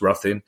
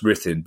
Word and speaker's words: Ruthin: Now, those Welsh listeners, my wife speaks a little bit of Welsh, but technically it's Rhythin. It's not pronounced Ruthin, it's pronounced Rhythin.